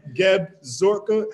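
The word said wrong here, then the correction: Seb Gorka: how you say his name? Zeb Gorka followed me the other day.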